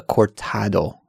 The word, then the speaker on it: cortado: I believe it was called a cortado.